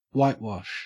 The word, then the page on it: whitewash: Australian English